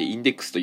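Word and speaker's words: 禁書目録: えー、で、これはもともとラノベ、ライトノベルで、とある科学のインデックス、えー、金書目録と書いてインデックスと読む、えー、このインデックスというシリーズがありました。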